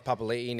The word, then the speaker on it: Papali'i: Well, Josh Papali, Josh Papali'i now.